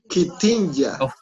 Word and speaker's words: Gdynia: Gdynia, ¿cómo sería? – Kitinja.